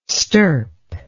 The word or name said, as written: stirp